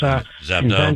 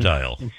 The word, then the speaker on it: Zabdiel: Zabdiel Boylston. – And that, Ezekiel, I can't pronounce his name. Ezekiel Boylston. – Zabdiel.